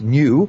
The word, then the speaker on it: nu: it was supposed to go, the next letter after lambda was nu, N-U, pronounced new, and you couldn't call it the new coronavirus because you'd say to somebody, hey, I've got new coronavirus.